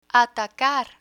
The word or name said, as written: atacar